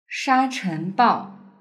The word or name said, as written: shachenbao